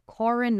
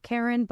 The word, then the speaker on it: Corin: Apparently pronounced Corin, not Karen, but I'll read it like Karen.